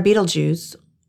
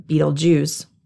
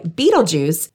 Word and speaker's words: Betelgeuse: The star Betelgeuse, or Betelgeuse, pretty sure I didn't say that right the first time, huh? Anyway, Betelgeuse is 427 light years away from us and is twice the size of Earth's orbit around the sun.